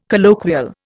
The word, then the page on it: colloquial: Indian